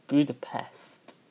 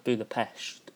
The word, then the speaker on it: Budapest: Now, some people pronounce it Budapest, which is actually the correct English version. However, the locals call it Budapest.